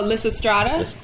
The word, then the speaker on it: Lysistrata: Oh, Lysistrata.